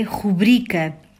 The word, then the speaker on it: rubrica: A palavra que designa uma assinatura breve é rubrica, proveniente do latim rubrica. Em português, a palavra é grave, sem acento. A sílaba tônica é a penúltima, bri.